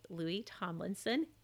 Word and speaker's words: Louis Tomlinson: pairing Harry Styles with Louis Tomlinson.